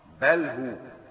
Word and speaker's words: بله: وهيا بمعنى أسرع، ورويدا بمعنى أمهل، رويدا فلان أي أمهل، وبله بمعنى أترك أيضا، بله نعم بله نطقها صحيح بله.